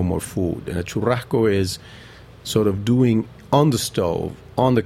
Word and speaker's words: churrasco: Tuna churrasco.